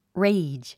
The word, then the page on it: rage: réidʒ; レェイジ